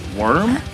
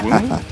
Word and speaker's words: womb: A worm? A womb? Am I saying this wrong? – Womb.